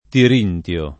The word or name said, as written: tirintio